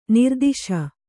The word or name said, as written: ನಿರ್ದೆಸೆ